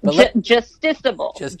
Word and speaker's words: justiciable: They say it's non-judiciable, if I pronounce that word correctly. – Justiciable. – Thank you.